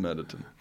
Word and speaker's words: Murderton: It's written Murder Town, but it's pronounced Murderton.